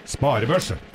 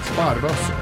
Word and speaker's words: sparebøsse: Sparebørse. – Bang! Bang. Det er ikke en børse. Det er bøsse. Det er bøsse. Mm. Bøsse. – Sparebøsse. – Ja.